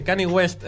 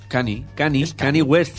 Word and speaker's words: Kanye West: Caño del Oeste, Kanye West. Qué bueno. ¿Cómo en serio? ¿Cómo? – Kanye West.